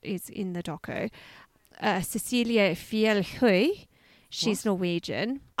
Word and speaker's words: Fjellhøy: I can tell you that is exactly how you pronounce it because I asked my boyfriend who's Swedish and he's like, because I was like, Fjellhøy? He's like, no, it's an O with a slash through it. It's Høy.